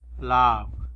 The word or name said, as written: lab